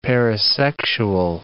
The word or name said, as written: parasexual